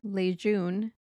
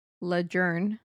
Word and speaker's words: Lejeune: Camp Lejeune, pronounced Lejeune, not Lejeune.